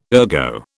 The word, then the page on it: ergo: British English